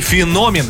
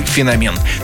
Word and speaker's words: феномен: Ну и феномен, именно так следует произносить это слово, хотя некоторые версии допускают иногда и феномен.